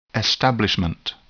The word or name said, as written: establishment